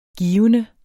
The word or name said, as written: givende